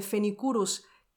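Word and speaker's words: Phoenicurus: pero si buscáis este nombre Fenicurus,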